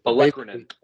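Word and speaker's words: olecranon: But basically, yeah, it's like the Olokrainen, I think. Something like that. I probably just butchered it. – Olekrinen. That's what it was. – Olekrinen. There you go.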